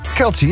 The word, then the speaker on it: Kelce: samen met Travis Kelce, zeg ik dat goed? – Kelce, ja.